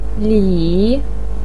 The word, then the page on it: li3: pinyin